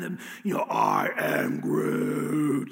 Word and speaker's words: Groot: Groot, which rhymes with root, is like this tree-like creature who's big and massive and strong.